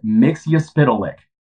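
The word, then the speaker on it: Mxyzptlk: We got official confirmation from, and this was a big thing for me, official confirmation from Warner Brothers and DC. It is Mixyuspidolik.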